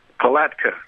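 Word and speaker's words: Palatka: Am I pronouncing that right? – Talatka. – Talatka in Florida.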